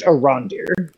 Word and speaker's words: Arondir: I believe it's pronounced Arondir.